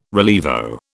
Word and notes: rilievo: British English

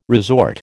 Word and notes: resort: American English

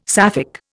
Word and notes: Sapphic: Indian

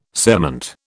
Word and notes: cerement: British English

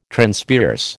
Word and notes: Transpierce: American English

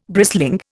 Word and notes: brisling: Indian

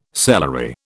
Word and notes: celery: British English

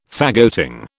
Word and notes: faggoting: British English

